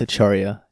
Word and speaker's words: Acharya: That's not how you say my name. – How do you say it? Acharya is my last name.